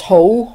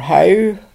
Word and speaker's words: Howe: My family name was Ho, or Howe, depending how you want to pronounce it, H-O-W-E.